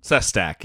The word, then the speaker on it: Sestak: A former Pennsylvania congressman, Joe Sestak? – Sestak, yeah.